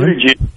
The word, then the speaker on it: Buttigieg: Buttigieg. – That nice job. I thought for sure he was going to do it. – Oh, man. – I did. I thought for... So, Buttigieg, Kamala Harris, your girl, those are the folks that are actually polling.